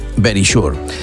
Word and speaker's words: Verisure: O Verisure, l'he dit tal qual, Verisure. Seria la pronunciació correcta. Verisure està com escrit, però és Verisure.